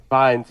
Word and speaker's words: Fiennes: I thought Ralph Fiennes, I hope I'm saying that right. – Fiennes, he was incredible.